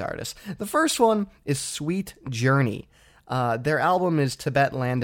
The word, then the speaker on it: Suite Journey: But they're Sweet Journey, and that's spelled S-U-I-T-E.